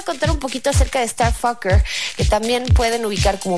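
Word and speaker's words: STRFKR: Es de Starfucker y este sí se escribe totalmente sin vocales. STRFKER R, que si lo pronunciamos tiene que decirse Starfucker, que pues no les voy a decir qué quiere decir, se lo imaginan seguramente.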